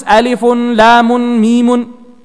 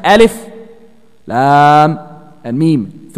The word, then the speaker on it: Alif Lam Mim: You do not pronounce this Alifun, Lamun, Mimun. Nope. Every other letter in the Quran is pronounced like that. You connect it. But here, you have to recite Alif, Lam, and Mim. Three separate things.